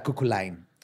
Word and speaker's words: Cuchulain: Se pronuncia Cuchulain. Cuchulain.